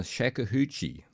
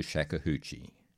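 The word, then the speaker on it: shakuhachi: However, here it's arranged and played on the shakuhachie, which is a bamboo flute, by the American-born, Australia-based Riley Lee, who, in 1980, became the first non-Japanese person to become Daishihan, or Grandmaster of the Shakuhachie.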